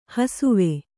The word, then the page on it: ಹಸಿವು: hasivu